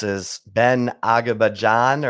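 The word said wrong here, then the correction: Agajanian: or Akajanian.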